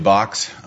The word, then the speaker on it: Bax: It's actually Box, but nobody gets that right, so I'll answer to either, sir. – Well, it's because it's spelled B-A-X, not B-O-X.